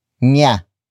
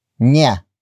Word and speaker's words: niya: After that, we have niya, niya, niya. Niya is spelled as N I Y A.